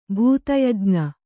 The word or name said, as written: ಭೂತಯಜ್ಞ